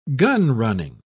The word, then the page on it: gunrunning: British English